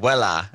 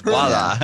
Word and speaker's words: VOILÀ: I was saying voila, but it's voila.